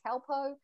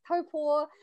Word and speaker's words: Taupo: And I am from Taupo, New Zealand, or as other people say, Taupo. But if you're pronouncing it properly, it's Taupo. And yes, I am the pastor of the Invisible Body.